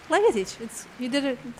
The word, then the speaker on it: Legatic: Legatic. That's an excellent job. – The third syllable, I think I screwed up. I didn't add this.